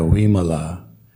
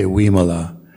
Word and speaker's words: Wimala: A V is a, uh, a sound somewhere between V as in vain and W as in Wayne. So I'm sure a lot of you know um, Ayya Wimala, right? So you can pronounce it as in, uh, even though her name starts with a V, you should say Wimala.